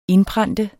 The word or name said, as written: INDPRENT